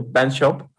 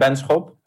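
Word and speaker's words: Benschop: I can pronounce it like you do, Benchop, okay. Because it's the place where I live. – Oh, it's a place. – Yeah. – Okay. – So how's it actually pronounced? – Lopik and Benchop.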